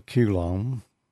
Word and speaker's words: coulomb: A, the coulomb? – Coulomb.